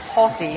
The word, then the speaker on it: haughty: Hottie. Did I pronounce it wrong? – No. You did. – Hottie. – Haughty. – Haughty. – Haughty. – Just a little bit more uppity.